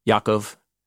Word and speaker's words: Yaakov: And these are the names of the sons of Israel, the ones who went to Egypt with Yaakov. – Jacob. – Yeah, sorry. I'm trying to use Hebrew pronunciation in my translation.